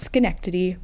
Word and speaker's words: Schenectady: I would just like to point out that I can pronounce Schenectady correctly because it is spelled S-C-H-E-N-E-C-T-A-D-Y. And you wouldn't think that it was pronounced Schenectady, but because of my limited knowledge of the pronunciation of small towns in upstate New York, I know how to pronounce it.